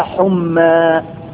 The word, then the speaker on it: حمى: السخونية اللي أنت بتقول عليها دي أقول عليها حمى بالألف المقصورة اللي هي تتكتب لا لكن تنطق ألف حمى.